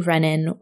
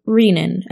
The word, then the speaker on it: renin: Our first paper brings me back to physiology lectures, as it uses an inhibitor to renin, or renin as some people pronounce it, an enzyme that is released by the kidneys that modulates blood pressure.